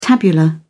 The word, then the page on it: tabular: British English